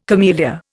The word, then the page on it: camellia: Indian